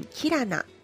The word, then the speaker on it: キラナ: 本日のゲストは癒し空間キラナ代表の阿部ここみさんです。よろしくお願いします。 – よろしくお願いいたします。 – キラアと書いてキラナと読むんですね。